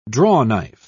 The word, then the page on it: drawknife: British English